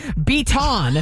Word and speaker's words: Beaton: His last name is Beaton. B-E-A-T-O-N. Okay? Beaton. I know that's how you say it.